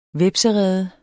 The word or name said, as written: hvepserede